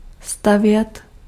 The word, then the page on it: build: /bɪld/